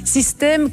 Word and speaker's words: Systaime: Mais pas System comme un système, System comme je t'aime. Enfin, ça s'écrit System, S-Y-S-T-A-I-M-E.